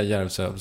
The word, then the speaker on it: Järvsö: Kan du inte säga Järvsö som det ska uttalas? – Järvsö.